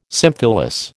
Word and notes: symphyllous: American English

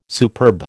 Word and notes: superb: American English

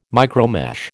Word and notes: micromesh: American English

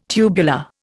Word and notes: tubular: Indian

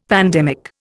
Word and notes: pandemic: Indian

